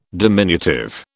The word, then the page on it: diminutive: British English